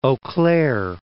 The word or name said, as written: Eau Claire